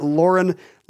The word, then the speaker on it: Lauren: and I'd like to show you that the way you were pronouncing his name is correct. As you put it, Lauren, like a girl's name.